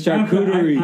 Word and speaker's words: charcuterie: I'm gonna let you do it this time because you didn't tell me that I mispronounced the charcuterie with all the hard T. – It's charcuterie.